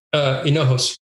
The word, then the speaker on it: Hinojos: Is it Paul Hinojos? Is that how you pronounce it? – It's Hinojos.